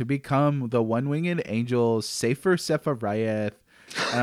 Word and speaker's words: Sephiroth: The original game itself pronounces Sephiroth in One Winged Angel. The yes, it does. – Eswanzi Teresi. – Several times. – Sephiroth. Yeah. So it's in there already. It's the one name that the game pronounces for you.